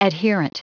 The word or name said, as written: adherent